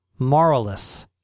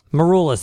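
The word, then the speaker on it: Maroulis: How do you pronounce his last name? Marolis. – I don't know who that is. That American Idol? – Yes, singer? – That's okay. Correct. – Yes. – Marolis. Marolis, that's it.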